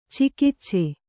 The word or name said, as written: ಚಗಚೆ